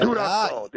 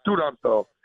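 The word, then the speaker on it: Turandot: Studanto. There's no T at the end. The T is there, but it's silent. Studanto. And it's that song, which is the greatest ever created by man.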